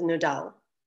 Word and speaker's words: Nudel: That's how you pronounce my last name, although since I moved here when I was 16, it's been Nudel this whole time. Fine by me. But you pronounce it as Nudel.